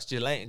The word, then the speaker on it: Ghislaine: Oof, that's a all right, so we know Ghislaine. Is it Glenn or Ghislaine? Gis, uh, Ghislaine.